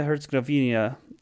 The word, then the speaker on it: Herzegovina: under 23 men's national team is in Bosnia Herzegovina.